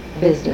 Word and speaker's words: Hvizdos: Father Vizdos. How do you spell that? – H-V-I-Z-D-O-S. – Okay. – H and that is silent.